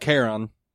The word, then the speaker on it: Charon: Yeah, C-H-A-R-O-N. – Yeah. Charon. – I think I always heard it like Karen. – And it might be, you know, for the longest time, I thought Cerberus was Cerebrus. – Yay for pronunciation. – Yeah. And the classic story when I was in school that I saw the word hyperbole and I said hyperbole. – It actually is, in fact, Charon.